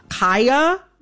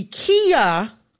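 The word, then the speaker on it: Khia: I am going to, in this conversation, pronounce Kaya, the rapper, with the long I. Kaya, okay? My neck, my back, mm-hmm. for those who need a refresher. Kaya, okay? Mm-hmm. The Kia Asylum is a mispronunciation of her name.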